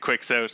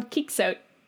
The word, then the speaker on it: Quixote: And so like then some English guy writes a poem that's dependent on you pronouncing it Quixote.